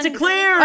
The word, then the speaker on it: Chanticleers: If the anyone, Chanticleers.